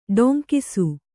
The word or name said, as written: ಡೊಂಕಿಸು